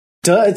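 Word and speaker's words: Duh (德): Lead them with excellence, or what we're calling, is it day? Is it pronounced day? – Duh, it's yeah, I guess duh is more strictly translated as virtue.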